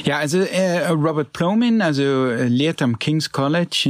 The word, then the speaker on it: Robert Plomin: Du hast dich mit einem Wissenschaftler auseinandergesetzt, Robert Plomin heißt der, habe ich das richtig ausgesprochen? – Ja, Robert Plomin, ja.